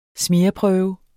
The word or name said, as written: smear